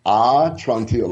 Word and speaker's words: Atrantil: Okay, so talk about I want to pronounce it right. It's atrantil, right? Atrantil. – It's atrantil, like okay.